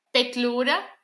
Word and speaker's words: Petliura: O nacionalista, que ele já fala, do Petliura, eu não lembro mais o nome dele, não consigo pronunciar o nome dele. Petliura, me ajuda. – Petliura.